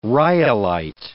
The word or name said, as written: rhyolite